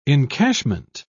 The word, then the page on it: encashment: British English